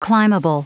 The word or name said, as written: climbable